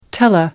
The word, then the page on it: tele: American English